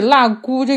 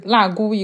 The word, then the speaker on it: 蝲蛄: 是，而且“ 腊姑” 这个第二个字有两个读音，一个是“ 腊姑”，一个是“ 腊骨”，就是三声，一个是三声，一个是一声，就是两种都好像都可以。